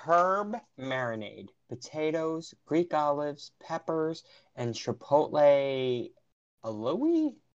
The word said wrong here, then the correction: aioli: It's aioli, honey. – Aioli. – Aioli. – But there's an A. – I know. It's confused. – I hate silent letters.